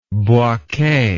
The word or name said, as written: Bouake